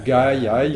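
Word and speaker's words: gajaj: Gay, c'est gaïaï, mais c'est effectivement très proche.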